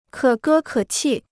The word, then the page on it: 可歌可泣: kě gē kě qì